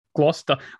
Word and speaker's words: Gloucester: It's Gloucester. – Gloucester. I think it depends about where you come from, mate. – It's actually a place in England and it's pronounced Gloucester.